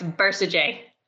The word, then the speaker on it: Bercegeay: No, it's Bercegeay.